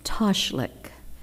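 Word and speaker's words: Tashlikh: So, in that, there is a service or whatever we want, a rite that the Jewish faith does, and it's called Toshlik, and that's the southern pronunciation. I have no idea how it would be pronounced in Israel, but anyway, and it means to cast off.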